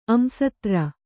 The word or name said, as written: ಅಂಸತ್ರ